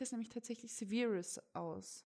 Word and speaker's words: Severus: Severus. – Sagt ihr Severus? – Ja. – Okay. – Was sagst du? – Ich bin mir nie sicher, wie es ausgesprochen wird.